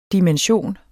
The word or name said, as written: DIMENSIONERNES